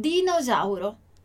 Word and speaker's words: dinosauro: No Brasil, em algumas regiões, esse som do D, como dinosauro, ele é utilizado, mas em grande parte a gente não vai pronunciar a letra D quando, né, ela vem antes da letra I.